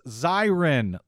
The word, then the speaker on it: Zirin: That came to us from a listener, Fred G, who says, it's Zirin, long I.